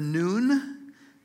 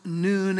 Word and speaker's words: Nun: It is pronounced noon as if it were noon on the clock, but it's N-U-N. And there's a character in our Bibles who was known as the son of Noon. There's a running joke that Joshua didn't have the father because he was the son of Nun, N-U-N. But Noon is how you pronounce it.